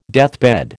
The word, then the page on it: deathbed: American English